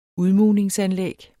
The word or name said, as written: udmugning